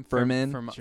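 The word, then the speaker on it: Fermín: Firmin. Is that how you say his name? Firmin. Okay, that was much better.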